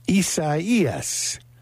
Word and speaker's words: Isaias: Isaias is how it's pronounced, which is kind of I don't know anybody named Isaias.